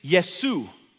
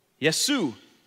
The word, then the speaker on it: Yesu: In Aramaic, what Jesus would have actually, what he would have spoke in Aramaic, the name Jesus actually is Yesu. Yesu.